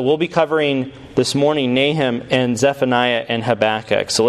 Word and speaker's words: Nahum: Nahum, that's how you pronounce that.